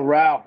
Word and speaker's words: Rao: Oh, Rao rhymes with cow.